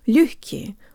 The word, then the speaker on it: лёгкий: Hey guys, and today you'll learn how to pronounce the word лёгкий, or easy.